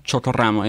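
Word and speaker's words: Chocoramo: Chotorramo, yeah. Roll your R. Ramo.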